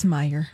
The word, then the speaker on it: Meijer: Is it pronounced major, by the way? I've never been there. Meijer. – Oh, I always thought it was Meyer. – Oh, could be. Sure. – I don't know. – It's Meyer.